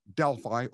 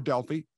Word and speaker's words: Delphi: She lived in a remote town called Delphi, or Delphi, can be pronounced either way.